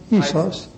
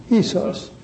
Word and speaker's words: isos: Isos? – Well, it's pronounced isos. Isos. Isos in Greek. I-S-O-S.